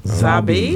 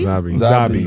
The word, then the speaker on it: Zabbi: Zaba. Like Zaba. Rabbi Zaba. – Say spell it. – Z A B B I. – Zabi. – Zabi.